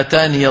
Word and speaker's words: آتاني: حفص رحمه الله رواها وصلا بياء مفتوحة، بياء ثابتة في النطق ولو كانت محذوفة في الخط مفتوح، فإذا وقف له وجهان، الوقف على حذف الياء والوقف بإيه؟ بإثباتها. قرأ حفص قوله تعالى: فما آتاني الله في النمل بياء مفتوحة في آخره وسطا، هكذا